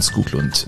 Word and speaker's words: Skoglund: Und Skoglund, in Schweden sagt man Skoglund. Das ist einer also der... – Also dieses O mit dem Strich nein, durch? Nein. Das ganz normale O im Schwedischen wird wie U ausgesprochen.